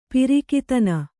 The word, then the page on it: ಪ್ರಾಕ್ತನ: Kannada